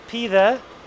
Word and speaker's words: pide: The things I'm interested in for this show, pide, which is spelled P-I-D-E, and lahmacun.